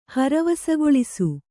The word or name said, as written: ಹರವಸಗೊಳಿಸು